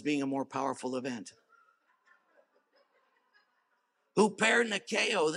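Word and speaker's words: hupernikeo (ὑπερνικάω): I even helped you pronounce it right there. It's hupernikeo. Hupernikeo.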